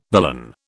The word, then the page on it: billon: British English